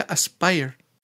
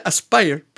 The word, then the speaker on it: Aspyr: o sea, se pronuncia Aspire, pero se escribe A-S-P-Y-R.